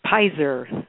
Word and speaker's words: Peyser: Pizer. – Pizer, P-E-Y-S-E-R.